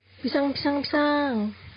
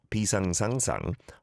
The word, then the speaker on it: 비상: I don't think I've ever heard that. 비상, 비상, 비상. You can say it like that again and again, the word for emergency, but not 비상상상.